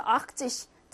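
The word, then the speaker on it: achtzig: 80은 악트 뜻이고요. 요거는 안 날라갔어, 얘들아. 뭐 아니면 T, 태 있잖아. 태 그대로 있어요. 근데 발음상 트가 잘안 들려서 악트 뜻. 트가 안 들려서 트가 날라갔다고 생각하는데 트 그대로 살아 있습니다.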